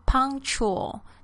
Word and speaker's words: punctual: 这边的 punctual, P-U-N-C-T-U-A-L, 注意一下它的发音 punctual, 这个 k 很轻的，因为被这个 chul 的声音带走了 punctual.